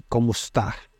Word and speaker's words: kumusta: For example, in kumusta or how are you, the stress falls on the second syllable.